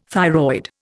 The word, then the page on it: thyroid: Indian